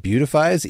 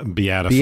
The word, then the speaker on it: beatifies: Is it beautifies, even though it's spelled beatifies? – Beatifies.